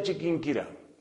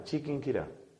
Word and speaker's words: Chiquinquirá: Noviciado de los padres dominicos en Colombia, ciudad de Chiquinquirá. Así se llama, Chiquinquirá. Hay que aprender a pronunciarla porque ahora resulta que algunas de mis amigas dominicas no saben decir Chiquinquirá, hay que saberlo pronunciar.